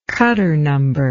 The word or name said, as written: Cutter number